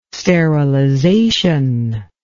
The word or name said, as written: sterilization